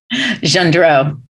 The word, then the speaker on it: Gendreau: Gendreau. So there you go. I always tell people my name rhymes with Marilyn Monroe, so then you can better.